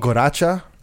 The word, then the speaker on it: Guaracha: So it's spelled Guaracha, but it's pronounced Guaracha. – Guaracha. Like with a W.